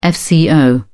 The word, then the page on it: fco: British English